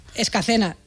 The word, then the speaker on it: Escacena: Nacida y crecida y vivida en Escalena del Campo, en Huelva. Escacena. Mira que te lo he dicho mal varias veces, ¿eh? Por ahí está, Escacena.